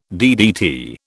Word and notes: DDT: British English

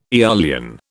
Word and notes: eolian: British English

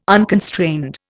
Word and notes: unconstrained: Indian

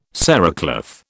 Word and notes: cerecloth: British English

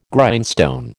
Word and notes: Grindstone: American English

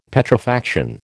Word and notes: Petrifaction: American English

